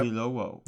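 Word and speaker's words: Oyelowo: and David how do you pronounce his name? Oilolo? – Yep. – Oilowo. – Yep, sure, that's it.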